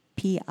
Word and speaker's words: Picoult: I think her last name is Picot. – I'm never French. – Sure how to pronounce it. – You know, I think the T is silent. Yeah. – Picot? – Picoult.